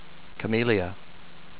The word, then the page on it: Camellia: ca-MEAL-ee-uh